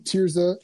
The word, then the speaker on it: Tirzah: I think you pronounce it Tears though. Tirzah, I think, yeah. I think Tirzah.